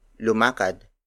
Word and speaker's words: lumakad: For example, lumakad, lumakad, lumakad. Lumakad is spelled as L-U-M-A-K-A-D.